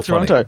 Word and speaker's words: Toronto: But even more so when I went to Toronto, did I say it right? – Yes. And what you did is you didn't say the last T, it's Toronto. That's, right. That's so Toronto.